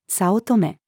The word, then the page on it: 早乙女: female